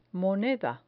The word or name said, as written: moneda